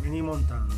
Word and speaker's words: Ménilmontant: Ménilmontant.